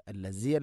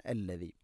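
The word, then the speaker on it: അല്ലദീ (الذي): ഹുവല്ലാഹുല്ലദീ ഹുവല്ലാഹുല്ലദീ അല്ലദീ, ദാലിൻ്റെ ഉച്ചാരണം ശ്രദ്ധിക്കുക. അല്ലസി അല്ല, അല്ലദീ.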